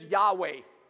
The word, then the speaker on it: Yahweh: Well, a lot of people, the pronunciation is Yahweh.